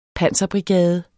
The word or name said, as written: panserbrigade